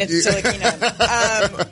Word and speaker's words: Ntilikina: You be careful with that enunciation. That pronunciation. – Okay. Frank Ntilikina. Frank Ntilikina.